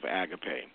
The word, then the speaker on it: agape: Okay, so let me talk about agape, which is the correct pronunciation of agape.